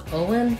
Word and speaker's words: Owen: The stress on Owen falls on the O syllable, and O is a diphthong in this name, Owen. Make sure you hold the O nice and long. And then the letters E-N say the schwa sound, and the N, un, Owen, Owen, Owen, Owen.